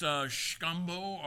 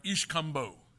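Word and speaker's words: Xcambó: It can be pronounced Xcambo or Ixcambo.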